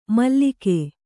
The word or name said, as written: ಮಲಕು